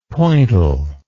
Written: pointel

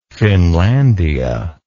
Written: finlandia

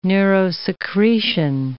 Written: neurosecretion

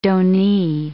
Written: donee

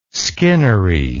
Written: skinnery

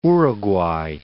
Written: Uruguay

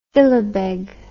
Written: fillebeg

